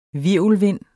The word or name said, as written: hvirvelvind